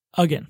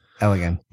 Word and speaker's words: Elgin: I can never remember how to say his last name. – Elgin. – Yeah, I got it. Elgin.